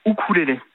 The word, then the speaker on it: ukulélé: Alors normalement on dit ukulélé. Le U en océanien se prononce ou, bon. Donc normalement on devrait dire ukulélé, c'est okay. La prononciation anglo-saxonne du mot.